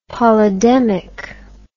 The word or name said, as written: polydemic